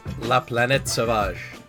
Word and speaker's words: La Planète Sauvage: La Planète Sauvage. That's how I've been told it's pronounced by French people.